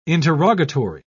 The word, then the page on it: interrogatory: British English